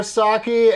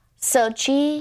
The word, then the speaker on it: Saki: Saki, is that, did I say it right? – Yes, that was amazing. That never happened. – Because people get it wrong all the time, don't they? – Yeah, I don't really correct them either. Because phonetically, I feel like they're right, you know? – Well, what's their first attempt usually? – Sochi. – They go Sochi.